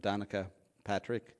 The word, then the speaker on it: Danica Patrick: So I listened to podcast from Danica Patrick the other day. Is that how you say her name? Yeah. Race car driver.